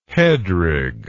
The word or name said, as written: headrig